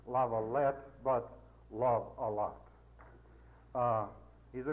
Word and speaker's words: Lavalette: He tells me that a lot of people mispronounce this word, Lavalette. You're supposed to have it let lava or something like that. Not love a lot.